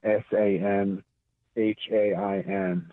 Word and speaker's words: Samhain: It's spelled Samhain, S A M H A I N. So beware of any word that looks like it's something, but it's pronounced something else.